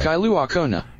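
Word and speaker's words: Kailua-Kona: Kailua Kona.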